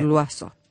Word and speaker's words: Loiseau: Miel punto lo azú sería pronunciado correctamente, pero ustedes tienen que escribirlo. – Pronunciado es lo azú. Se escribe loiseau.